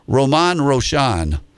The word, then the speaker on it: Grosjean: But I Googled it last night, and as a lady, will pronounce it for you several times, and it's Romain Grosjean. So I wasn't even close.